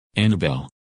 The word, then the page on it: Annabelle: English